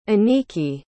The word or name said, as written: aniki